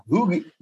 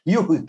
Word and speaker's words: Huyghe: Hughie, Hughie. – H-U-Y-G-H-E. – H-U-Y-G-H-E.